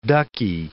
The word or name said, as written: ducky